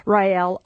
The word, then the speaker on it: Rael: Rael, R-A-E-L, although I have purchased the domains.